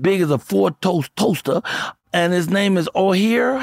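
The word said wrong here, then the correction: Oher: How you pronounce his last name? – I think it's just Michael Orr. – Just it's pronounced Orr.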